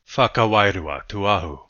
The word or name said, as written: Whakawairua Tuahu